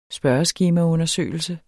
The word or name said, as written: spørgeskemaundersøgelse